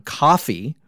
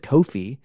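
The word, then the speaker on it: Ko-fi: Uh, if you'd like to support the show, uh, have a page on coffee or as wrestling fans might instinctually pronounce it, Kofi. Uh, that's K O F I, K O dash fi dot com slash the next pillar AEW.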